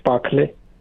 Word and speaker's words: Pakhale: Mahesh Pakhale. – How do you say P-A? – P-A-K-H-A-L-E. – K-H-E-L-E? – K-H-A-L-E. – A-L-E.